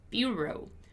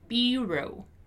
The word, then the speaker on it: bureau: Okay, so here, first thing that I want you to pay attention to is the pronunciation of the word bureau, because it's a word that comes from French, right? It comes from French, but it has a very specific pronunciation in the English version, which is bureau, bureau, bureau, bureau.